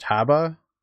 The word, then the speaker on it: Haba: So Jason had actually suggested, and I'm probably going to butcher the way it's pronounced, Haba. – I think that's right.